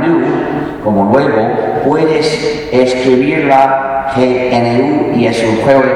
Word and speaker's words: GNU: En castellano es aceptable pronunciarlo GNU, como si comenzara por ñ, lo que es también el nombre del mismo animal.